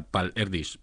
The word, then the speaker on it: Erdős: que de hecho se pronuncia Paul Erdős, pero aquí en el país se dice, todo el mundo lo llama Paul Erdős, así que no voy a confundir.